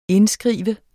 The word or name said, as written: indskrive